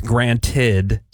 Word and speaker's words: granted: I mean, there's a thing like, oh, take it for granted. – Right. – Yeah. You know, which is like the stone granite. Like, no, it's granted. – Take this granite.